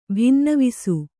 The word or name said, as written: ಭಿನ್ನವಿಸು